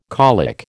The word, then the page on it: colic: American English